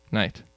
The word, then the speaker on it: knight: But now we don't pronounce it knife. We just say knife. We don't pronounce it knight. We just say knight.